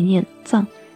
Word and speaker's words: 藏: 请大家关注我们的公众微信号“ 藏玉阁翡翠”，藏为多音字，也念藏，西藏的藏；玉为玉器的玉，阁是阁楼的阁。感谢大家的收听，依然是用声音为您读玉。我们下期再会。